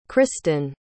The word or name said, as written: christyn